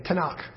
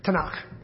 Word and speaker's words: Tanakh: T-N-K, Tanakh. So it's an abbreviation that they pronounce, Tanakh.